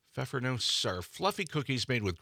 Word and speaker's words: Pfeffernuss: How do you pronounce that? – Pfeffernuss? – I remember us last year talking about how to pronounce this word. A year ago, we were also arguing over how to pronounce this. – Pfeffernuss? – I think it's Pfeffernuss, yes.